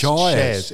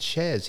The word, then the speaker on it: Syaz: And her name is spelt S-Y-A-Z, but it's... – S-Y-A-Z. – It's pronounced Shaz. It's pronounced Shaz.